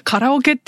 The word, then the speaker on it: カラオケ: これからカラオケ、後ろのバックステージでやりますって、アナウンスする時にカリオケって言っちゃって、普通にその場で、あれなんで、なんか、 Why am I pronouncing this in English? とか、カラオケって言うんだよとかって言って。